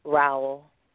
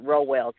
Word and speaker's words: Rowell: Rowell. – Rowell? – Yes. – For some reason, my mouth just don't. – And some people pronounce it Rowell, too.